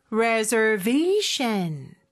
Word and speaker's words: reservation: Reservation. 자, 강세 들리셨죠? 그리고 S 발음은 Z 발음으로 reservation.